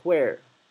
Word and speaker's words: where: La palabra where, donde, puede pronunciarse where, como lo estoy pronunciando ahora, o solamente como si fuera una U. Where, ¿sí?